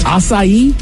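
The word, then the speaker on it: açaí: Acai? That's how you say that? I've been calling them Aki bowls.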